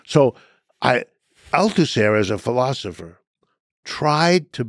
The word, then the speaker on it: Althusser: Althusser. Luis, the Luis is spelled Louis, Luis mm-hmm. Althusser.